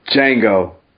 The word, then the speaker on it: Django: Next comment comes from Django. – What? – The D is silent.